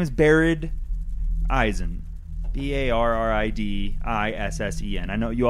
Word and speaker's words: Barid Issen: Could you just spell that once more? – And slower. – B A double R I D I double S E N. – That's pronounced Issen? – Barid Issen. – Issen.